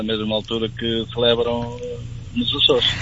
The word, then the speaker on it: Açores: Exatamente, tal e qual, conforme se escreve Açores, as ilhas, na verdade.